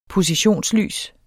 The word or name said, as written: positionslys